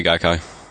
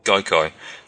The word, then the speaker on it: Gaikai: How do you say it? Do you say Gaikai? – Yeah, I say Gaikai. – Gaikai.